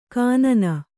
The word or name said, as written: ಖಿನ್ನ